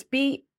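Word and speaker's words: Busbrygge: Busbrig or Bus, yeah, how do you pronounce this? It's Busybrygge. I want to say Busy Brig, Busy Bridge. – Which sounds nice and colourful. I think it survives into the modern day as Busbrig.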